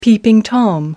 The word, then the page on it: peeping tom: American English